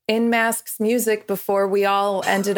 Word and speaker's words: In Masks: That's in, ma- in mask, I in masks just like spelled out phonetically yeah.